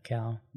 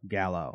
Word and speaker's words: Gallo: I'm happy for Alicia Gallo, not okay. Callow. – Not Callow. – Gallo.